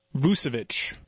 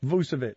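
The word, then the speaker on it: Vučević: Vujicic? How do you pronounce his name? – Vucevic. – Vucevic.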